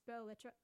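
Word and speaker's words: Belles Lettres: Bella's Letters, pronounced Belle Letra.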